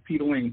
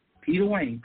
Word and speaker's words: Peta Wane: On all social media websites, my name is Peter Wayne, P-E-T-A-W-A-N-E. Let me spell that to you again. Peter Wayne, P-E-T-A-W-A-N-E.